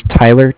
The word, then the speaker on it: Tyler: Okay, my name is Tyler, T-Y-L-E-R. – Mm-hmm. – I'm with Bank of Cardiff. It's spelled C-A-R-D-I-F-F, as in Frank Frank.